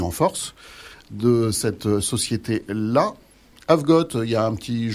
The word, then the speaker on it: Avegott: Il y a, y a, un jeu de mots, donc on a voulu ben, franciser le nom Avgot, puisque ça s'écrit A-V-E-G-O. e g o ah, pardon, AVEGO2T.